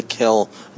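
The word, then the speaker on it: kiln: Note that I did not use an N when I pronounced kill. – Yeah, both are correct. Technically, I do want to let you know, if you look in the dictionary, both are correct. But real pottery people, I'm told that Rufus Keeler stopped anybody who pronounced the N.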